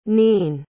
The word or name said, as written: nein